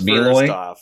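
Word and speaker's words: Meloy: Oh, it's Malloy? – Yeah.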